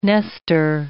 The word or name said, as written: nester